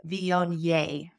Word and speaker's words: Viognier: But we do pronounce it Viognier. So the yay at the end, yay Viognier. Now, let's talk about a classic style of Viognier from the Northern Rhone.